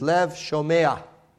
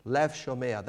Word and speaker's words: lev shomea: What Solomon he asks for, he says, is lev shem. Let's get the pronunciation right for Hebrew scholars among us. Is lev shomea, lev shomea.